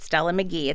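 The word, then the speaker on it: Meghie: I've talked about her on this show before at a time when I thought you pronounced her name Stella Maggie, but you don't. You pronounce it Stella Magee. It's M-E-G-H-I-E.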